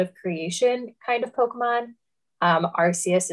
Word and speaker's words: Arceus: But of course, when you think religion and Pokemon, for me at least, I think of Arceus or Arceus, as I believe it is canonically pronounced now. They've shifted that one and I wasn't ready for it.